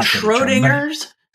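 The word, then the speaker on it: Schrödinger's: And, you know, they're calling it, it's kind of like Schrodinger sample. I'm still not able to pronounce that. – I mean, Schrodinger's? – Thank you.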